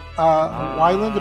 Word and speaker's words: Weiland: We're talking about Scott Weiland.